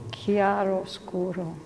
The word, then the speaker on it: chiaroscuro: Chiaroscuro.